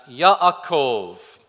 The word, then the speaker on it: Yaakov: In Hebrew, the name is pronounced Yaakov.